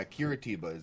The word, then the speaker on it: Curitiba: Yeah, Curitiba is how you pronounce that.